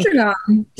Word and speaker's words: Sandringham: Anyway, but some of the stories, that the biggest ones that came out of the volume 2 drop, was the meeting at Sandringham. I'm sure I'm saying it wrong. – Sandringham.